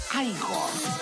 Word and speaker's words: Igor: Usted debe de ser Igor. – No, se pronuncia Igor.